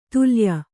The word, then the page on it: ತಲ್ಲೀಯ: tallīya